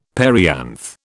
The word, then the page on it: perianth: British English